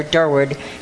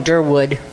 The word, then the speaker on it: Durward: Okay, I was saying that Rhonda did a great job, which is a difficult job, but Durward McDaniel is spelled, or Durward, D-U-R-W-A-R-D. So many people call him Durwood, but it isn't. It's D-U-R-W-A-R-D.